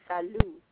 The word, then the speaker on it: salut: The T is silent, so it would be salut.